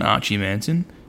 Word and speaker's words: Archie Manton: So one's your son, Archie Manton.